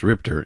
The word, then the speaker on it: RIPTA: known as RIPTA, but pronounced RIPTER in Rhode Island.